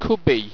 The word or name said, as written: qubi